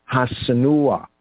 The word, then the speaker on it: Hassenuah: But here we have Hasenua. Here is spelled as H-A-S-S-E-N-U-A-H. So we have Senua and the definite article Ha. Ha, Senua, right? Hasanua. Now it's spelled in the A-V as H-A-S, 1-S-E-N-U-A-H in 1 Chronicles 9:7.